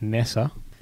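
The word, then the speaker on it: Neser: Do you two know much about Michael Nisa? – My South African spies tell me it's Nessa. – Good, no, Nessa.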